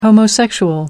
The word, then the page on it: homosexual: American English